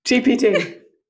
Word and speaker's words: GPT: You said GTP. – Fuck. ChatGTP? GPT? – GPT. – GPT, chat GPT.